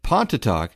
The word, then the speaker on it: Pontotoc: Box 7, Pontotoc. It's spelled P-O-N-T-O-T-O-C, Mississippi, zip code 38863.